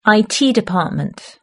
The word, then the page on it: IT department: British English